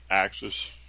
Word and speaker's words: AXS: I think they call it Access.